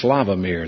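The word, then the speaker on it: Sławomir: It's pronounced Slavomir.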